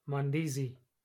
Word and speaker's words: Mandazi: Mamba Badazi? – Bro, it's pronounced Mandazi, otherwise known as Mahamri.